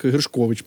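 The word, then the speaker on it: Gershkovich: Так, Гершкович, мабуть, правильно ставити наголос.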